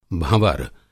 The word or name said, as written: भँवर